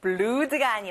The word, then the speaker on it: blood: OO가 두 개면 원래 으 소리 나는데 blood가 아니라 blood. That's right.